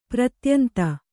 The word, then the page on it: ಪ್ರತ್ಯಂತ: pratyanta